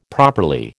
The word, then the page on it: properly: American English